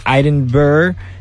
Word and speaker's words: Edinburgh: Think Edinburgh.